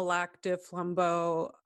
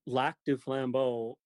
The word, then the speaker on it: Lac du Flambeau: So, all right, so let's back up a little bit, because you said you're from the Lac des Flambeaux Ojibwe Indian Reservation in northern Wisconsin.